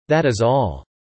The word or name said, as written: That is all